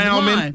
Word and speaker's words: salmon: Salmon. That's salmon. – Salmon has that. Well, that L is supposed to be silent, ain't it? – Kind of, sort of.